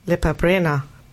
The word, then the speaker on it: Lepa Brena: Nämlich Lepa Brena.